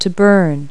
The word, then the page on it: burn: verb